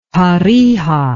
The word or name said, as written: tarija